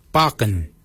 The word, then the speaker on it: baginy: For beard, we say barken. Barken is spelled B-A-G-I-N-Y.